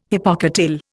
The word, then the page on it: hypocotyl: Indian